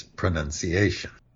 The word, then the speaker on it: pronunciation: And the tip off here is that if you see it in writing, pronounces with the O-U, P-R-O-N-O-U-N-C-E. And there's no O in the word pronunciation. So it neither appears nor is it pronounced.